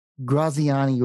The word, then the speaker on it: Graziani: And by way of introduction, today we have Rick Graziani with us.